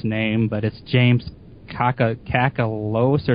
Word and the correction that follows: Kakalios: or something like that. Is that how you pronounce it, Zane? – I think it's James Kakalios.